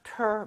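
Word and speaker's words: theatre: T-R-E도 가능합니다. 그리고 발음도 심지어 트레가 아니라 ter 맞아요.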